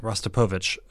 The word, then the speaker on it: Rostropovich: Mstislav Rudopovsky. Man, I wrote it out so I would pronounce it right, but if I just read it, I would have had it right anyway. Mstislav Rostropovich.